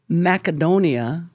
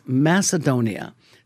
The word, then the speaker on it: Macedonia: It's like, for example, Sunday, I have been pronouncing Macedonia when it's Macedonia.